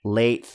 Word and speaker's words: Leitch: That's definitely That's how you say it. Late.